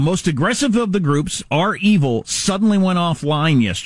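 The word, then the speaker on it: REvil: You may be aware of the ransomware group Reval. Is that the way you pronounce it? It's capital R, capital E, V-I-L. How do you pronounce it? – Or is it R-Evil? – R-Evil, yeah. Is that the way they say it?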